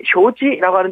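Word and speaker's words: 쇼지 (手机): 중국에서 이 수기, 쇼지라고 하는데 이걸 갖다가 휴대폰이라고 하거든요. 그래서 그 지능적인 휴대폰이다 할 그 스마트폰이다 할 그 지능수기, 지능, 쇼지, 지능, 쇼지.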